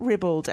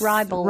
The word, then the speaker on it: ribald: Maybe it's not ribald as I... – No, it's ribald. – Ribald. People suggest that. I think I remember someone that I trusted and, you know, maybe they were a Brit pronouncing it ribald. Mm. But I did have a quick check and it looks like I am wrong and you are right.